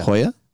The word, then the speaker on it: Hoja: Hoa del Sol. – Hoa. – Hoja. How do you say Hoja? – Hoya. – Hoya. Don't look like Hoya. – J-O-A.